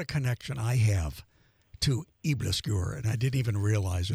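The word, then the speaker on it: aebleskiver: Her name is spelled P-I-M, last name Over, O-V-E-R, G-A-A-R-D. Eblisqueur is how it's pronounced. It's spelled A-E-B-L-E-S-K-I-V-E-R, a new take on traditional Danish pancakes.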